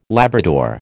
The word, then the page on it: Labrador: American English